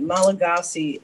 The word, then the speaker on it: Malagasy: Thank you for pronouncing it Malagasy. Every Malagasy person who's listening to this will just, they will beam because that's the perfect way to say it.